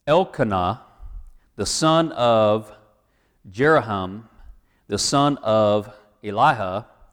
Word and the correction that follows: Elihu: That's actually Elihu, I believe.